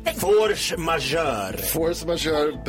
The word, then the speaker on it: force majeure: Force majeure, bra franskt uttryck.